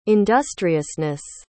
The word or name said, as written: industriousness